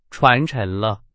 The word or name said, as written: 沉